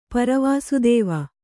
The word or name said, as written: ಪರವಾಸುದೇವ